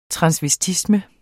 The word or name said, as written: transvestisme